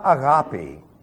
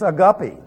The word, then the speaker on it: agape: I had a seminarian come to me once and he says, you know, you're mispronouncing that word. Really, he says, it's not agape. It's a guppy.